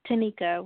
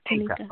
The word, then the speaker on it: Tanika: Yes, Tanika with an N like Nancy. Tanika.